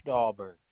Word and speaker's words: Dahlberg: Bruce Dahlberg.